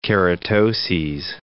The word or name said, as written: keratoses